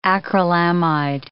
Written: acrylamide

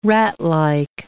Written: ratlike

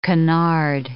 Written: canard

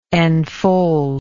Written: enfoldment

